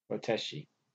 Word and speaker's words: Botich: It's B-O-T-I-C-H. Botich? – Boteschi.